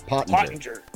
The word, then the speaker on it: Pottenger: And I'm going to help everybody with the pronunciation of my last name. – Okay. What is it? – German. – It's German. – Pottinger. – Pottinger. Okay. – There we go. – Pottinger. I apologize.